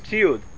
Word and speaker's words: tziud: Equipment would be in Hebrew, how do you say equipment? Tziud. Yeah, it's a tziud.